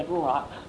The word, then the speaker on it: Ruach: Now, the wind, the Holy Spirit is called the Holy Ruach in the Hebrew. That's how you pronounce his name.